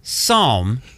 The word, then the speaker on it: Psalm: Kim and Kanye revealed the name of their fourth child, Psalm West. It's a silent P.